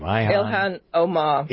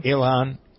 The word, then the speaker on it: Ilhan: How do we pronounce that woman's first name? – Ilhan Omar. – Ilhan, okay.